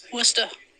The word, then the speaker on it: Worcester: Worcester. – is the correct pronunciation of that.